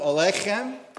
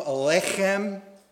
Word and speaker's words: Aleichem: In order to say Aleichem properly, the accent is on the K in Alech. Just pretend you have a bagel stuck in your throat. Try one more time. Shalom Aleichem.